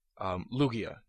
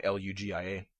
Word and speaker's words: Lugia: Lugia, not Lugia or Lugia. Some people go ahead and make it a soft G instead of making it a hard G, with the G in the Pokemon's name, Lugia.